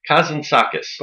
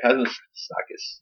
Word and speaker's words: Kazantzakis: Kazantzakis. – By that Greek guy. – Kazantzakis.